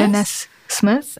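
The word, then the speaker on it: Danez Smith: Von, wie spricht man den aus? Danny Smith? – Dennis Smith. Ist eine nicht-binäre Person. Smith. Genau.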